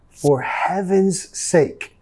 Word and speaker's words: for heaven's sake: Nota acá, for heaven's, la S vamos a pronunciar como una Z. For heaven's, for heaven's sake.